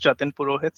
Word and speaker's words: Jatin Purohit: This is Jatin Purohit. So that's how I pronounce my name.